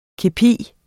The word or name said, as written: kepi